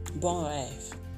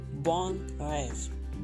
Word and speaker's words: bon rêve: How do you say Sweet Dreams in French. Bon rêve. Bon rêve. Bon rêve. Bon rêve. Rêve. Rêve. Bon rêve. Sweet Dreams.